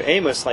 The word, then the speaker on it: Amos: Amos, like Amos and Andy. – Okay, I pronounced it correctly. – Or the biblical book, Amos in the Old Testament.